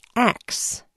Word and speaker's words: ask: It's not uncommon to hear that word pronounced axe. To many, that's a mispronunciation, but as NPR's Shireen Marisol Meraji reports, axe is as old as English itself.